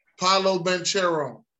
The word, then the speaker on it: Paolo Banchero: Paolo Banchero.